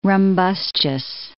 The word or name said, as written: rumbustious